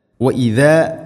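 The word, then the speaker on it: وإذا: വഇദാ ഖീല ലഹും, വഇദാ ദാലിൻ്റെ ഉച്ചാരണമാണ് പ്രത്യേകം ശ്രദ്ധിക്കേണ്ടത്. വൈസ എന്ന രൂപത്തിൽ ഓതരുത്.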